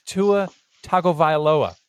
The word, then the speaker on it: Tua Tagovailoa: But what makes it even more harder to digest is the fact that at 16, immediately behind Aaron Rodgers, is Tua Tagovailoa, if I said that correctly. – That's definitely a – yeah, you did.